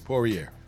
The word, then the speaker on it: Poirier: Yeah, Poirier.